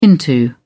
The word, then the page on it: into: British English